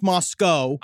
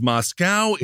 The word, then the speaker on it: Moscow: It is pronounced Moscow. – I did not realize that. – Idaho. – I thought it was Moscow.